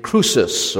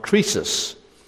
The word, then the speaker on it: Croesus: Sardis was once the capital of an ancient kingdom called Lydia, which was ruled over by a very powerful and wealthy king named Croesus, or sometimes it's pronounced Croesus.